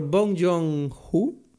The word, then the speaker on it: Bong Joon-ho: O sea, esta película es increíble, dirigida por Bong Joon-ho, si lo he dicho bien.